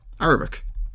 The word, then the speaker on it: Arabic: How do you pronounce that word? – Arabic. – Arabic, not Arabic.